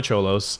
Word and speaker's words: Xolos: It's spelled X-O-L-O-S, the Tijuana Cholos.